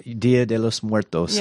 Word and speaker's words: Dia de los Muertos: Like it's like just a fun kind of thing, like really colorful, like the Dia de los Muertos would be. But man, that would be, did I say it right? – Dia de los Muertos. – Yeah, okay.